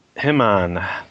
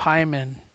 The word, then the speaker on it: Himon: I'm glad you pronounced it like that instead of Hyman.